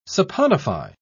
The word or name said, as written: saponify